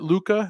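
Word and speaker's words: Lucca: So tell me a little bit about your books, the Walls of, is it Luca? Is that how you pronounce it? – Yes, it is.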